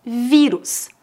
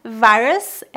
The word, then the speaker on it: vírus: Vírus. Por isso devemos pronunciar coronavírus. Em português não é virus, é vírus.